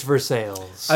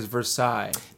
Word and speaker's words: Versailles: I believe the word you're looking for is Versailles. – Now, if we're talking... – Because in France, they don't pronounce any consonants. – Yeah, At the end of words.